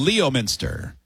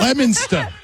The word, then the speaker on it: Leominster: Leominster. – Leominster. – Leominster. – Oh, yeah. Stuh. It's definitely spelled like that. L-E-O. Minster. I see it. – Leominster. – Leominster. – Stop pronouncing the R so hard.